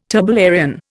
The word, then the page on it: turbellarian: Indian